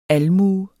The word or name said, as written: almue